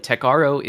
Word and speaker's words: Tecaro: Tecaro is the canonical pronunciation of it, and it is intended to be one letter off from Tech Bro.